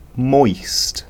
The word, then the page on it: moist: British English